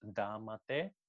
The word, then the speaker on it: Δάματε: κάτι το οποίο διαβάζεται ως «Δάματε», δάματε, το οποίο φαίνεται ότι σηματοδοτούσε τη θεά Δήμητρα.